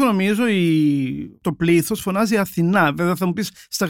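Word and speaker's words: Athena: ε, ο τρόπο που είναι γραμμένο στα λατινικά διαβάζεται Αθήνα. Α, ναι. ναι. Οπότε, ο ίδιο μα είπε βέβαια που βρέθηκε και στην Αθήνα και στην προβολή τη στέγη ε, ότι εμπνεύστηκε το όνομα από τη Θεά Αθηνά, αλλά στην πραγματικότητα ο λατινικός τίτλος προφέρεται Αθήνα.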